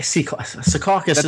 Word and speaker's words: Secaucus: Hey, Secaucus is. – No, that's the right way to say it.